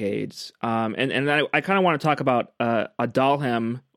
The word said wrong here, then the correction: Aduhelm: or Aducanumab. I hope I'm saying that right. – Aduhelm. It's easier that way.